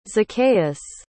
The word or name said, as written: zaccheus